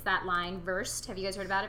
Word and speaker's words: Versed: Versed, like V E R S E D, like Versed. Oh, conversed. Like well versed.